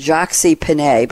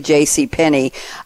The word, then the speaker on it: J.C. Penney: Erika has sent me a quote from, well, in New York where I come from, Erika, we use the French pronunciation, Jacques Penney, but it's really J.C. Penney.